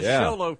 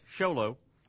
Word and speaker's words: Xolo: They call it the Sholo for short, Sholo. It's spelled X-O-L-O.